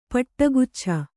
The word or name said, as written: ಪಟ್ಟಗುಚ್ಛ